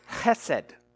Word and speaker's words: chesed: That is the Hebrew word chesed. That's an important word for you to know. Chesed. Well, it's C-H, it's the C-H, hard C-H sound, E-S-E-D.